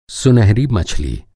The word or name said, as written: मछली